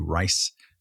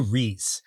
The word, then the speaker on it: Rhys: Surprise hit, critically acclaimed Apple TV production in which Matthew Rhys, if I'm pronouncing that correctly, which I'm not. It's actually Matthew Rhys.